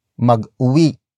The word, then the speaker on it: mag-uwi: Meanwhile, adding the mag prefix turns uwi into mag-uwi. Mag-uwi. Mag-uwi. Mag-uwi is spelled as M-A-G hyphen U-W-I.